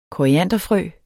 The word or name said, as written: korianderfrø